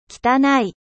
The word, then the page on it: sale: French